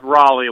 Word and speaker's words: Raleigh: Yeah, it's actually pronounced Raleigh, like the city.